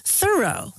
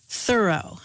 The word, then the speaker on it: Thoreau: writing about how their friend's name was pronounced Thoreau, with the accent on the first syllable.